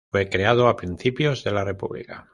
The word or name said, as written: creado